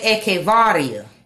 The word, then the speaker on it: Echevarria: ECH is like echo. So I would say Echevarria.